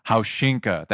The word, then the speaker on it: Haushinka: Haushinka, that's how you pronounce it.